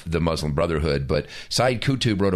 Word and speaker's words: Qutb: I think sometimes I pronounce it Qutby, but it's Qutb.